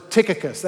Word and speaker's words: Tychicus: Tychicus. That actually is the way that you pronounce this first one, okay, for sure.